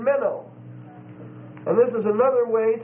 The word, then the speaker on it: meno: You spell minnow, M-E-N-O. And it's a long O. Minnow.